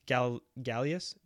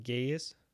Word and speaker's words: Gaius: So I'm gonna butcher his name, but there's Roman Emperor Gaius. No, Gaius. You want to try and say it? G-A-I-U-S. Take a look.